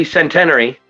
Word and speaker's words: Centenary: I, I pronounce it wrong. It's actually centenary.